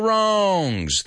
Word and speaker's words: wrongs: There are no unrightable wrongs. There, that word wrong, remember the W is silent. Wrongs.